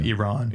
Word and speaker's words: Iran: Yeah, it started off with the reporter telling him that he was mispronouncing their country's name, and which is a common mistake, I think, especially here in America. – He called it Iran, and he said Iran. It's Iran.